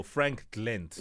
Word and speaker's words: Frankglent: First of all, how do I pronounce the name of the business? – Okay, it's a Frankglent. – Oh, Frankglent.